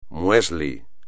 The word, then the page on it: muesli: espagnol